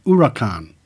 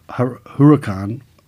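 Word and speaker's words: Huracán: The Spanish origin of the Huracan name means that the H is silent, pronounced it as Huracan, to impress future car buyers and coffee buddies. See, I pronounced it Huracan on purpose, incorrectly, at the start, and made up for it at the end.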